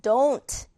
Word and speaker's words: don't: 그래서 발음은요. 여기서는 두였지만 도 이렇게 발음입니다. don't.